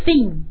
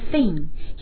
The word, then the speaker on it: thin: woman woman 女人有一部电影叫做风月俏佳人那么它的英文原名就是 pretty woman 那也有许多啊翻译方法有的翻译成漂亮女人 so pretty woman 接下来 thin thin 表示瘦的啊，他妈妈长得非常的瘦，可以说 his mother is very thin. His mother is very thin. 那注意这个发音啊，同样的 th 发音，它的发音和 thank you 啊，three 它们都是一样的 th 发。